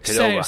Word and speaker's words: Qdoba: That's not how you say it. – You got to say it quick. Qdoba.